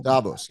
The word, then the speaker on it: Davos: They want to give us or what, I don't know, I mispronounced their name. – Davos, yep.